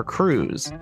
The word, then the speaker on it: krewes: Central to Mardi Gras in New Orleans are crews. And as Jenny pointed out, it is spelled K-R-E-W-E-S.